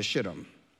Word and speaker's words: Shittim: It says, then Joshua, son of Nun, secretly sent 2 spies to Shittim.